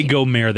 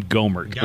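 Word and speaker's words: Gohmert: Don't say Gomer. That makes it sound fancy in French. He pronounces his name Gomert, like Gomert Pyle. – Gomert. – Gomert. He is proudly Gomert.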